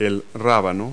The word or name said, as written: radish